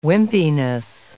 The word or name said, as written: wimpiness